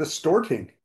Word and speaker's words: Storting: Yeah, not to mention the Storting. That's how it's pronounced.